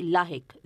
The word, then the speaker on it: لاحق: ہاں اس کا صحیح تلفظ وہی ہے جو ہم لوگ بولتے ہیں، یعنی لاحق زیر کے ساتھ۔